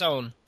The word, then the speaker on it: Eoin: Okay, well, he's an Irish author, so his first name is spelled E-O-I-N. – Oh, it's Owen.